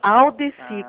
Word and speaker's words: Aldeci: Não, sou Aldeci, como todo mundo fala.